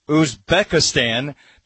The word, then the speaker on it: Uzbekistan: Colombia beat Uzbekistan by a score of 3-1. Is that close? – You nailed it.